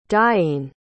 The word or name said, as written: deiene